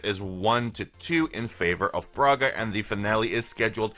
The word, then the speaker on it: Braga: And following that, we're going to see Freiburg versus Braga.